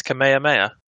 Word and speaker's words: Kamehameha: Yeah, it's Kamehameha.